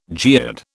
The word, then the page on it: geode: British English